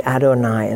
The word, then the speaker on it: Adonai: He was the priest of God Most High, Lord Almighty, Adonai is how you say that.